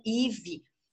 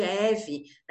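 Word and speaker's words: Evie: Ou, por exemplo, imagina você, eu que chamo Ive, e meu nome se escreve E-V-I-E, e a pessoa me chama de Eve, né?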